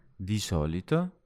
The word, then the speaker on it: di solito: And it's two words. Di and solito. There's a pronunciation make that learners usually make. It's not di solito, it's di solito.